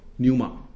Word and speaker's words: Pneuma: 那在神学上有个叫圣灵论啊，圣灵这个字的希腊文叫 Pneuma 啊，P 不发音，叫 Pneumatology 哈、